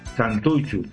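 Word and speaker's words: Santutxu: Ambos son los trabajan en un club juvenil de allá en, del País Vasco, el Santuchu, Santuxu, Santuchu, TX se pronuncia como CH, Santuchu.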